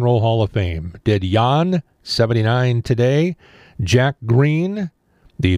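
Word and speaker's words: Jann: Jan Wehner, W-E-N-N-E-R, Wehner, careful. The Californian, whose first name is pronounced Jan. I was worried about the second name, but first name is Jan, actually. I said John, so Jan.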